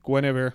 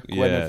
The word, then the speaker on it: Guinevere: Guinevere. – Guinevere.